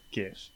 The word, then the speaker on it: GIF: The first word is graphic. It's a hard G. – It's a hard G. Oh, Libby, do you say GIF?